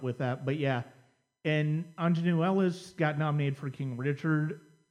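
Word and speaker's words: Aunjanue Ellis: Judi Dench for Belfast, Kirsten Dunst for Power of the Dog, and how do you pronounce her name from King Richard? I don't. – Anjanou Ellis.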